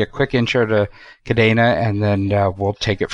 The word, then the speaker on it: Kadena: Yes. Kadena. It's a nod to the Spanish word for chain and the Spanish pronunciation. – Well, that is cool. It has an E, so the E is really sounding like an A, which is why I had to make sure I did it right.